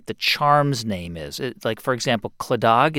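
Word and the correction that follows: Claddagh: is that how it's pronounced? – Clada, sorry, forgive me.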